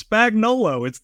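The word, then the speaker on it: Spagnuolo: It's such an easy name to pronounce, Spagnolo. There's nothing extra there. Nolo, that's it.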